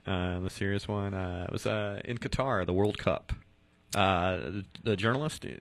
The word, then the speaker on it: Qatar: Qatar, I believe, is how it's pronounced.